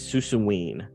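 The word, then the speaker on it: Suicune: I believe the pronunciation is Susuwine is the Pokemon.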